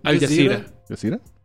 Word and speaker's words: Al Jazeera: Al Jazeera. Yazira, Al Yacira.